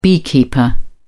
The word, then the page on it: beekeeper: British English